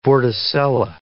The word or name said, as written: vorticella